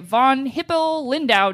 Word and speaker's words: von Hippel-Lindau: So in 2007, a study of doctors and geneticists who had studied dozens of McCoy descendants noted an unusually high rate of, I can't pronounce this, but I'm going to try, von Hippel-Lindau disease. – I think you nailed it.